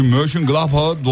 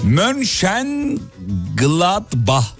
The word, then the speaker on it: Mönchengladbach: Şu Mönchengladbach'a doğru telaffuz et.